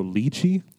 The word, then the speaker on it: lychee: So lychee, is that how you say it? Lychee? – No, I thought you said lychee first. – I didn't. She said the second one is the one that you say. Yeah, Marla says lychee also. – Lychee. Okay, so we've been saying it wrong. Lychee. – I've heard people say it both ways, but I didn't know what the correct way was.